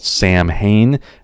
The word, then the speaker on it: Samhain: Now, Samhain is spelled S-A-M-H-A-I-N, but it is not pronounced the way that it's spelled. It looks like it's spelled Samhain, and that is how you will hear it pronounced sometimes on TV shows. I know Supernatural does a pretty terrible job of this, but it's actually pronounced Samhain. Now, I should mention there are a few other pronunciations out there that you may have heard, but none of them sound anything like Samhain.